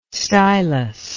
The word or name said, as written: stylus